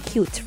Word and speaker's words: QT: When we're talking about QT Robot, just like its name suggests, it's spelled as QT, but I'm calling it the Cute Robot.